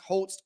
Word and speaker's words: Holz: We talked about Nick Holz being hired as the OC, and just so I correct myself, it's Nick Holz, like Lou Holtz, the old coach from Notre Dame, not Nick Holes. Okay. It's pronounced Holtz.